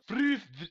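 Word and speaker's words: Fürth: Furth? F-U-R-T-H? Furth, Germany? – F-U-R-T-H? Wouldn't that be Furth? – It's Furth. – Furth, that's what I said. I thought I said it.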